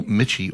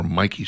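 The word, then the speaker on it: Michie: and be in beautiful Michi or Mikey Stadium, depending on how you choose to pronounce it.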